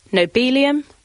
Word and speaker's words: nobelium: To begin with, there's the matter of how to pronounce its current name. Nobelium, because it comes from the same root as the Nobel Prize, or nobelium, modelled on the way we say helium.